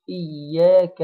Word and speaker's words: إياك: إيا كده، جبنا الحركتين، نجيب الكاف، إياك إياك، كاف مفتوحة، نجيبها برضو على هيئة المبتسم لأنها حرف مرقق، فنقول إياك نعبد إياك إياك، وخلي بالنا من الشدة بتاع الياء، علشان في بعض الناس ما بتجيبش الشدة كويس بتقول إياك، وبعض أهل العلم قالوا إن من أخطاء المصلين نطق كلمة إياك إن هم ينطقوها إياك، ليه؟